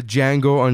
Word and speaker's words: Django: My number three is The Django Unchained. – Mm. The D is silent.